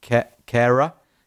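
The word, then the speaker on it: Kehrer: Sorry, Ian Holyman says we have to pronounce it Kerrer. Because that's the German pronunciation.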